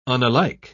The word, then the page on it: unalike: British English